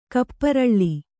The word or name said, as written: ಕಪ್ಪುರಾಳ